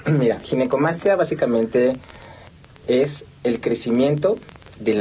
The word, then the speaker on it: Ginecomastia: Pues muchas gracias, y sí, definitivamente creo que es una, para empezar la palabra, le decía, ¿cómo se pronuncia? Ginecomastia, ginecomastia. – Sí, exacto. Ginecomastia.